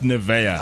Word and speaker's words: Nivea: There are people who know that it's pronounced Nivea.